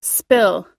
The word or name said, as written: spill